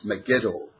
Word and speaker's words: Megiddo: The word means the Mount of Megiddo or Megiddo. I pronounce it as Megiddo.